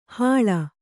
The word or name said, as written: ಹೂಳ್